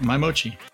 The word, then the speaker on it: My Mochi: Yep, My Mochi.